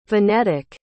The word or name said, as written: venetic